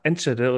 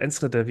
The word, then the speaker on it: Enschede: Und damit konnte man dann den ganzen Monat durchs Münsterland fahren, bis nach Holland, also bis nach Enschede oder Enschede, wie man es ja richtig aussprechen würde.